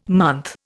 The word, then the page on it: month: Indian